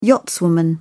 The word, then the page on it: yachtswoman: British English